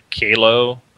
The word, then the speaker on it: Qalo: So there's a company called Kalo. That's how I pronounce it. Q-A-L-O or whatever.